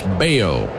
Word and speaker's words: Bello: Brian Bayo, spelled B-E-L-L-O, but pronounced Bayo.